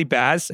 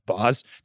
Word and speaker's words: Baz: There's a chef named Molly Baz. I think it's actually pronounced Boz, that I really like.